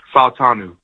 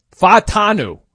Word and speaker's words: Fautanu: But yeah, this is how you say his last name. – Troy you know my first name. Fautanu. – Fautanu.